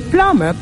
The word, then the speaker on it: plumber: In plumber, plum is actually the word, the root word. The addition of ER does not change the pronunciation of the word. So you still have the word as plum, even in plumber.